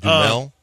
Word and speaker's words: Duhamel: It's Dumel. It's spelled D-U-H-A-M-E-L.